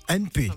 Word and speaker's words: N&P: je ne sais pas si je prononce bien, c'est NP, c'est ça ou NP. – NP, d'accord, voilà, je préfère NP.